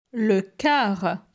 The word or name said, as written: Le car